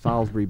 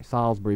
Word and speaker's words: Salisbury: Yeah, they pronounce it the American way to Salisbury Beach. The I is silent. It's a silent I. Salisbury Beach, you know.